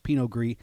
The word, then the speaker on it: Pinot Gris: But when it's domestic, it's just G-R-I-S, and then the S is silent, so just Pinot Gris.